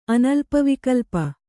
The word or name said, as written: ಅನಲ್ಪವಿಕಲ್ಪ